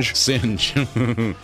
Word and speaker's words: Singh: Singh. Because he singes the cup.